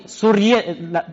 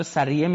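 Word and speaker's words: سُرّیه: در حرم خلیفه 700 زن و سوریه تلفظ درستشه من بررسی کردم از جا سریه میگن سوریه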